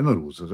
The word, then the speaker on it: Nowruz: Nowruz, pronuncio bene? – Perfettamente, Nowruz, perfetto, senza Z dura, ma va benissimo.